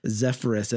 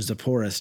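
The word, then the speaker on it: Sepphoris: which raised the near-to-Nazareth city of Zephorus and led, or Zephorus, depending on your pronunciation.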